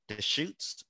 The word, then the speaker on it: Deschutes: Deschutes. I think that's how you say it.